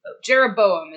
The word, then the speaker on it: Jeroboam: Jeroboam is how I would pronounce it.